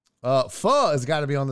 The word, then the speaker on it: pho: P H U poo. They're like, bro, that's pho.